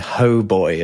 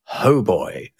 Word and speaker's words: hautbois: Yeah, because the term oboe is an Italian take on the French hautbois, which is spelled A-U-T, meaning high, and bois, B-O-I-S, meaning wood, because it's a relatively high-pitched wood instrument. But that got rendered into English as hoboy.